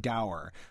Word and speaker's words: dour: Start with D-O-U-R. – I personally say dour, which is considered a variant pronunciation and acceptable. The older pronunciation would be more like dour.